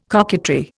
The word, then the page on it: coquetry: Indian